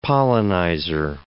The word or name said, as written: pollenizer